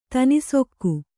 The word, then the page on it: ತನಿಸೊಕ್ಕು: kannada